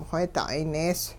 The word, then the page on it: wheeda'ay nees: hupa